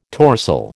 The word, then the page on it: torsel: American English